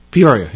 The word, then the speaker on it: Peoria: Peoria, yeah.